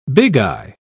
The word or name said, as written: bigeye